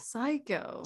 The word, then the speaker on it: Saikow: So it's spelled SAIKOW. – Yeah, I had to spell that in my head. – Yeah, it's like the way you say it is the way you spell it. Psycho.